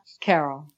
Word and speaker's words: Carole: My name is Kadrolsha Anastasia Carole.